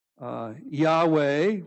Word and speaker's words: Yahweh: Yahweh, we would say, as we try to pronounce it, the consonants are missing.